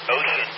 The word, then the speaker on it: Wouxun: I'm always arguing with myself about the same thing, but there's a radio I'm definitely considering getting, and I pronounce it Ocean, which is W-O-U-X-U-N. It's like Wooshun or something like that, but I believe it's pronounced Ocean.